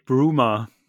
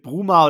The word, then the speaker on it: Bruma: Ja, okay, weil das Lustige mit dem Bruma oder Brüma, glaube ich, wird er da ausgesprochen, holländisch, ja.